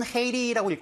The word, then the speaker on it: Haiti: 영어로는 Haiti라고 읽고요.